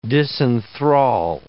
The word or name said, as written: disenthral